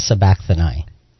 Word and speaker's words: sabachthani: At the ninth hour, Jesus cried out with a loud voice, Eloi, how do you say that? – Eloi, Eloi, lama sabachthani.